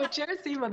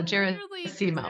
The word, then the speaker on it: Gerasimo: Today's guest is Pilar Gerasimo, correct? Did I get it wrong again? – Yeah. No, Gerasimo, that's right. I always tell people it's like Seymour, but with a Jera on the front. So Gerasimo.